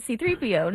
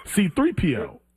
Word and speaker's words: C-3PO: C-3PO. Like, I don't know. – Your emphasis is on the C. – C-3PO. – Mine's is on the 3. Mine's on the 3. P. C-3PO.